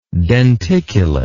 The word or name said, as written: denticulately